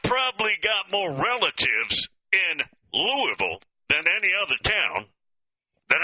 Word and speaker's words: Louisville: You can tell a carpetbagger by the way he pronounces Louisville. Is that what he says? It's Louisville. If you're a member, if you live there, if that's your home, it's Louisville. It's not Louisville.